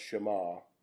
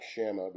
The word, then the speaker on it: Shema: And this man's name was probably pronounced Shema. It looks like Shama, but it's probably pronounced Shema.